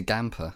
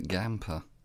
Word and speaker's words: Gamper: Yeah, I say Gamper. It's like Stamper, but Gamper.